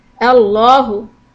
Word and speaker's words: Allahu: Allahu. We can pronounce the lam in Allahu either light or heavy.